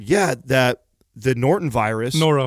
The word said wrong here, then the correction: norovirus: Norovirus. Norovirus. – Is that how you say it? – N-O-R-O, Yeah.